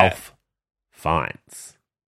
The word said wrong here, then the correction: Ralph Fiennes: No, it's Rafe. You pronounce it Rafe Fiennes.